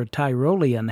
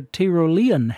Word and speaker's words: Tyrolean: Before that, it was Billy Cotton and his band with what I would pronounce as a feather in her Tyrolean hat, but which Alan Breezy Breeze sings as a feather in her Tyrolean hat.